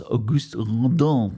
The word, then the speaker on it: Auguste Rodin: Es müsste laut einer englischen Wikipedia-Seite die Nahaufnahme des Höllentors des französischen Bildhauers Auguste Rodin sein. Da du nie Französisch hattest, glaub mir, das wird so ausgesprochen.